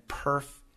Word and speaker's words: PIRF: And if you are interested in learning how to speak like an American, to speak as naturally as possible, then perf is perfect for you.